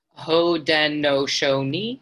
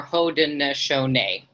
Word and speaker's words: Haudenosaunee: Haudenosaunee is pronounced. Haudenosaunee, like a knee, or Haudenosaunee. Both of those are right.